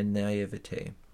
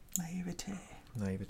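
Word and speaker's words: naïveté: I have not lost my mind, nor my doctrine, just my naivete. – Naivete. Trying to add some French to your English here. – How do you say it in English? Naivete? – Yeah.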